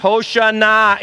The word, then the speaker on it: Hoshana: How do you say that in Hebrew? Hosanna, Hosanna, Hosanna, Hoshana in Hebrew.